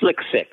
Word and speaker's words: SLC-6: This launch pad is called SLC-6, which is pronounced Slick-6.